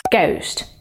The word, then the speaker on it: ghost: Ghost. А теперь рифма. Rhyme. Запомни, H не читается.